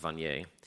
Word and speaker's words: Vanier: There's a man named Jean Vanier, although he's French, so I'm sure it's pronounced Vanier.